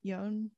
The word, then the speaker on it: Yeoh: And then I was also realizing like the connection between Henry Golding, who's in it, and then Michelle, how do you pronounce her last name? Yeon? – Yeah.